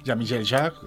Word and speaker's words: Jean-Michel Jarre: Es Walking Cousteau de Jean-Michel Jarre. Nunca lo digo bien, pero bueno, es fácil de encontrar. – Jean-Michel Jarre. – Jean-Michel Jarre, pues, francés perfecto, pues Walking Cousteau.